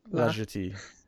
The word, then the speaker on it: La Jetée: La Jetée.